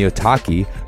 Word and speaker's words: Otaki: And the Otaki, rhymes with souvlaki, is an aggressive high-performance shoe designed to hold the downturned shape even after repeated lashings.